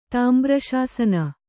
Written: ತಾಂಬ್ರಶಾಸನ

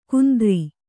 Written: ಕುಂದ್ರಿ

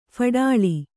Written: ಫಡಾಳಿ

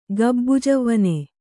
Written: ಗಬ್ಬುಜವ್ವನೆ

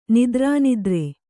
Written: ನಿದ್ರಾನಿದ್ರೆ

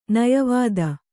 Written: ನಯವಿದೆ

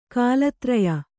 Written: ಕಾಲತ್ರಯ

